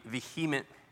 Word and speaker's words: vehement: That would be vehement. Vehement is how you say it.